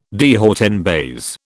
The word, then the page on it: de haut en bas: British English